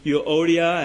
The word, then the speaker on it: Euodia: Now, this friction was due probably to internal conflicts, like that between Euodia and Syntyche.